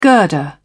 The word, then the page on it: girder: British English